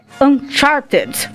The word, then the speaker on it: Uncharted: O primeiro é Uncharted. Falei certo? – Falou. Fora do mapa.